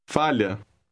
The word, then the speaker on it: falha: It has an LH combination. This is pronounced like the middle of the word million. Listen and repeat word 13. – Falha.